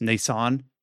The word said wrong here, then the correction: Nacon: the French publishing agency that ended up grabbing Spiders. – I think it is Nacon.